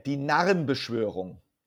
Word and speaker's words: Die Narrenbeschwörung: Die Narrenbeschwörung, which means, can you pronounce it for me, please? – Yeah, Die Narrenbeschwörung. – Oh, said that, you see, much, much better.